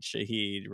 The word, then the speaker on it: Shaheed: Rashid Shaheed rhymes with speed, and he is very fast.